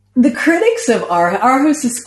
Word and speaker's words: Aarhus: Am I pronouncing Aarhus, that? – Yeah. – Aarhus.